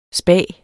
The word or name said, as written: spa